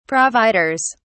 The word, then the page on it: providers: British English